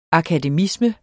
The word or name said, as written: akademisme